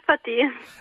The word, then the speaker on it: Fatihi: Ho detto bene il numero, eh, il nome eh, Faiti? – Sì, sì, sì, buonasera. – Lo vorrei sì. sentire ripetuto da lei così poi lo copio. – Chemè Fatih.